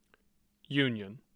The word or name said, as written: Union